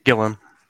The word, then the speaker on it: Gillon: Or is it pronounced Gillon? – Gillon.